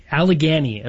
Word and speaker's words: Allegany: Allegory. – It wasn't Allegory. – Allegory. Allegany is how I called it. – Can you spell it for everybody? – A-L-L-E-G.